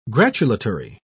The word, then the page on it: gratulatory: British English